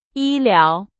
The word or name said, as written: 医疗